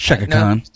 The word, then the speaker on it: Chaka Khan: That's Chaka Khan.